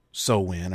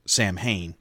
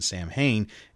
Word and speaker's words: Samhain: As a matter of fact, it's not even Samhain. It's pronounced Samhain, as I've already said, and it's not a Celtic god.